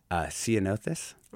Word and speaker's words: Ceanothus: But I'm probably going to pronounce this wrong. Ceanothus? – Mm-hmm. Yeah. – Did I say, get it right? – That's right. – Yep.